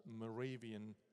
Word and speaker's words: Moravia: It's called Moravian Revival. Now, anyone who knows anything about geography, Moravia, that's the proper pronunciation, is sort of southern East Germany and Western Czech Republic.